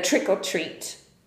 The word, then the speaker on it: trick or treat: Czyli trick or treat e, bardzo często pisze się or, czyli lub, takie o z apostrofem, bo wymawia się to bardziej takie jak trick, e, trick or treat. Tam w zasadzie tego r nie ma, tylko jest takie o.